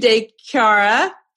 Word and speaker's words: De Chiara: All right, guys, I am here with Sue De Chiara.